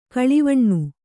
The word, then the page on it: ಗಿಳಿವಣ್ಣ: kannada